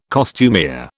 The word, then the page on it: costumier: British English